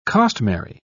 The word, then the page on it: costmary: British English